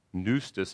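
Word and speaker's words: Pneustos (πνευστός): Theos, God, pneustos, and actually starts with a P, all right? But if you want to transliterate it, you can just put an N so you pronounce it right.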